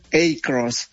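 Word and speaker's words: Across: Pero la marca lo está pronunciando como Acros.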